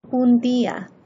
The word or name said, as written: un dia